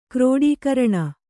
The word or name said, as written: ಕ್ರೋಡೀಕರಣ